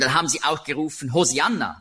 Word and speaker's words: Hosianna: Also rette doch ist Hosianna. Hosianna ist eben die griechische Aussprache von Hebräisch Hoshianna. Das kommt daher, im Griechischen gibt es keinen Sch-Laut.